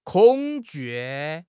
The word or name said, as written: 恐惧